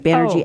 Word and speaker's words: Banerjee: It's like Banerjee Energy.